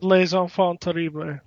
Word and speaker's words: Les Enfants Terribles: And obviously, it's revealed Solidus Snake is the third brother in the Les Enfants Terribles, or whatever you pronounce it. – Les Enfants Terribles. – Terribles.